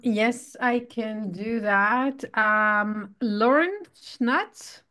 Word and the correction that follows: Sanchez: also. – Sanchez. – Okay, I just killed her name. I'm sorry, I butchered her name completely.